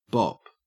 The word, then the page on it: bop: Australian English